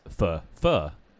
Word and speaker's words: pho: But not many people pronounce Pho. They call it Pho.